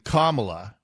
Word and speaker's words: Kamala: So I thought Kamala Harris's first name was, at first I thought it was Kamala. And then I started saying Kamala, which I guess is right. That's correct.